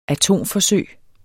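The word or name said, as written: atomforsøg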